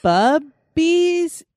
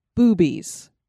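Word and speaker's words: Bube's: I looked it up because I'm like, is it bubbies? Is it boobays? – Boobs, boobays. It is boobies. B-U-B-E apostrophe S. Boobies Brewery.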